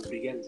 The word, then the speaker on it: Brian: Dan itu bacanya aku lebih nyaman Brian sih. Padahal kalau di Jakarta tuh teman-temanku manggilnya Brian. Maharapena.